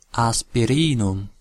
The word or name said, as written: aspirinum